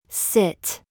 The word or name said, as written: TIGHT